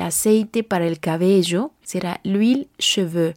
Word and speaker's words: l'huile: L apostrophe H-U-I-L-E espace C-H-E-V-E-U-X. L'huile cheveux. La palabra aceite es una palabra femenina en francés existen dos tipos de H, recordemos H muda y H aspirada, H muda de origen latino, H aspirada, palabras extranjeras, ninguna de las dos se pronuncia.